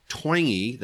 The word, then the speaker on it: Twenge: And it is by Dr. Jane Twenge. That's actually how it's pronounced. It's spelled T-W-E-N-G-E if you want to look up the book.